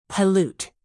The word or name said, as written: pollute